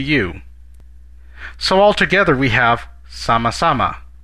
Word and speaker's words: sama-sama: Another way, this time a more informal way, of responding to a phrase of gratitude is sama-sama. Sama-sama. Let's break it down by syllable. Sa-ma-sa-ma. Ma. Now let's hear it once again.